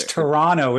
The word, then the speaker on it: Toronto: The second T is silent. – Oh, it's Toronto, isn't it? – Toronto.